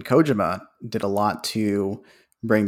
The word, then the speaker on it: Kojima: You know, I'm sure that Dennis Villeneuve or whatever is kicking themselves that Hideo Kojima, there's a wrestler named Satoshi Kojima in New Japan Pro Wrestling. I think we pronounce the name Kojima, not Kojima. So I go back and forth between the pronunciations.